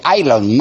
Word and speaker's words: Elon: Elon, se escribe Elon, pero hay que decirle Elon Musk.